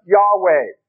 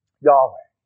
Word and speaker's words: Yahweh: Most scholars today think it's not proper to pronounce the name Jehovah, but Yahweh. actually just four letters that come over to the English language. YHWH. Yahweh is a possible way to pronounce it.